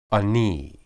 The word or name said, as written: ani